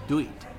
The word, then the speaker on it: duit: Because in Malay, money is duit. Duit. D-U-I-T. And because some Chinese cannot pronounce D, they say lui, lui, lui.